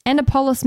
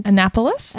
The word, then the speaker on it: Annapolis: And also, she lives in, I don't know how to say this, Annapolis, Maryland. – Annapolis? – Annapolis.